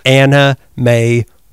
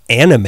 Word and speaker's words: Anna May: Anna May Wong, which I guess I pronounce her name too fast, because both you and Melissa were like, she's not anime.